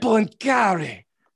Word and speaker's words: Poincaré: I'm sure I'm not saying this right, but Raymond Poincaré. – I'm going to need you to pronounce that correctly. So I Raymond Poincaré.